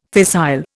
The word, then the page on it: fissile: Indian